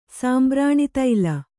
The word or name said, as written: ಸಾಂಬ್ರಾಣಿತೈಲ